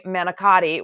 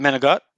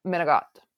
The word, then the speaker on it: manicotti: So I'm like the okay girl making, it was really cute, making okay antipasta and okay manicotti, which by the way is not the proper way to say it if you're really Italian. But that's how do you say it? Manigot? – Manigot.